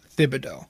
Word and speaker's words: Thibodeau: You pronounce Thibodeau. But when you say Tibbs, it's just the T that you pronounce.